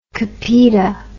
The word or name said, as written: copita